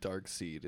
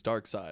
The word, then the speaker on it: Darkseid: No, we, Miguel actually got really pissed off at us for our Justice League review because we kept saying Darkseid, and he's like, it's Darkseid.